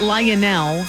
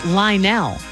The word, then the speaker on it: Lionel: It's not Lionel, three syllables, it's Lionel, two syllables.